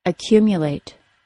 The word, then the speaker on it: accumulate: The mu syllable of the word accumulate sounds like mya, accumulate.